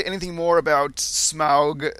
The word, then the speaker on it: Smaug: And it is pronounced Smaug, not Smog, and has nothing to do with pollution.